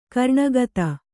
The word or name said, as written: ಕರುಣಕಥೆ